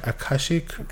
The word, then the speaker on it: Akashic: And some of his stuff that he went through that I have not mentioned is the Akashic Records. Am I saying that right? Akashic Records, yeah.